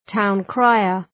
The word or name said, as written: town crier